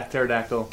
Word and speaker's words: pterodactyl: Yeah, pterodactyl. – The P is silent? – Yeah.